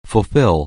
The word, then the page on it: fulfill: anglais